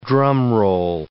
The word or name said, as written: drumroll